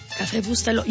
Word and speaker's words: Café Bustelo: Café Bustelo.